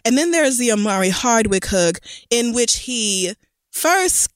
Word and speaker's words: Omari Hardwick: Omari Hardwick, however...